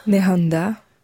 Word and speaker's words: Nehanda: vous aider avec la prononciation. Nehanda.